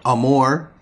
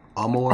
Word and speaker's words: Amour: Amber Amour, I think that's how you pronounce her name, is A-M-O-U-R. Amour. Amour.